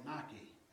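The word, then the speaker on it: Nike (νίκη): It's a word called Nike. And it's spelled the exact same way that your shoes are spelled.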